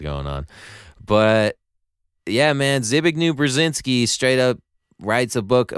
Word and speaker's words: Zbigniew Brzezinski: It's Zbigniew Brzezinski.